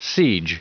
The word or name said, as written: siege